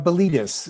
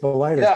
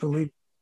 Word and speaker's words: Miletus: Yeah, and it was the Daughters of Miletus is how it was pronounced. – Oh, I'm sorry. Okay. – That's okay. – Sorry. – The way it's spelled, you think it's Miletus, but it's Miletus.